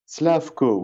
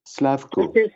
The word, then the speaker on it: Slavko: Slavko, oui, c'est croate. Slavko. – Ça s'écrit comment ? – S-L-A-V-K-O. – S-L-A-V-K-O.